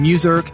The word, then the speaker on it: Muserk: With me today from Midtown Manhattan, New York City, Paul Goldman from Muserk. Let me spell it for you. It's M-U-S-E-R-K.